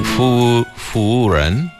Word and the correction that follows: 服务员: 服务员. – Oh, 服务员.